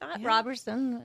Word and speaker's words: Roberson: Okay, no T. There's no T. There's no T. – No T. No T. – But then it had to make you think how to say it, right? – Roberson.